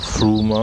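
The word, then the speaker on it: Frome: Und dann fährt man aber nach Froome, das schreibt sich Froome, wird über Froome ausgesprochen. Und dort gibt es in der schönsten,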